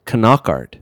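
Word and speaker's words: Knockaert: Further research on Knockard determined that there is some precedent for pronouncing the hard K. – Knockart. Knockart. – Mm-hmm. – Really? – It's more than once I heard French people pronouncing the K. Knockart.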